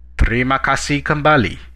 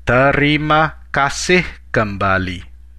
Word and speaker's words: terima kasih kembali: The last way, this time the most formal way of responding to a phrase of gratitude is terima kasih kembali. Terima kasih kembali. Let's break it down by syllable. Terima kasih kembali.